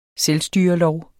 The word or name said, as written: selvstyrelov